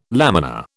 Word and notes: laminar: British English

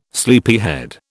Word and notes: sleepyhead: British English

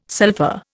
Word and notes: sulpha: Indian

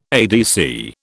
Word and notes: ADC: British English

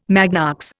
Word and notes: magnox: Indian

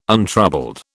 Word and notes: untroubled: British English

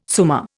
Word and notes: summa: Indian

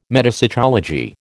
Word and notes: metapsychology: American English

